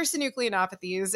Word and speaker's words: synucleinopathies: Those are synucleinopathies.